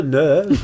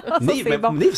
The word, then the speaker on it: Neev: Et non pas Neuve. – Non, ça s'écrit N-E-E-V, mais c'est très bien. Non, non, mais des fois, tu vois, c'est la liste. On va parler avec N-E-E-V. – À cause des deux E.